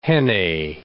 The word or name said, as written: jennet